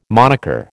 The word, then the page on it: monicker: American English